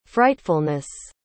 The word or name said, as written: frightfulness